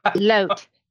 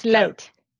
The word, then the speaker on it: Loht: Loht. It's Loht.